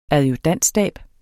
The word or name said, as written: adjudantstab